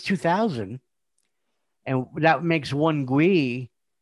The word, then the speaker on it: gwei: So gas fees in Ethereum, they call it I pronounce it as a GWEI, G-W-E-I, right?